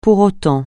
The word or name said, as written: pour autant